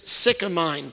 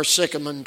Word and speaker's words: sycamine: you might say unto this sycamine tree," or sycamine tree, both pronunciations are right,